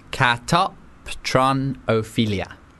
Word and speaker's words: catoptronophilia: It has landed on catoptronophilia. Catoptronophilia. – Are you saying that phonetically? – Yep. Catoptronophilia. – Okay. – That is 100% the pronunciation.